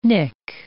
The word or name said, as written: nick